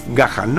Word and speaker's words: Gahan: Pues si veía al cantante, al Gahan este, que ya os digo que no me sale bien pronunciarlo nunca, Dave Gahan, ¿no? – Correcto.